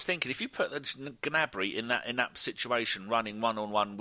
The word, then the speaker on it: Gnabry: Oh, Gnabry. I think someone told me you do pronounce the G in German when you say it in German. – Yeah, you do.